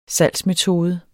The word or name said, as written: salgsmetode